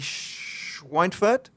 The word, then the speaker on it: Schweinfurt: It was DJK Schweinfurt. – Yeah. – Is that good pronunciation? Yeah.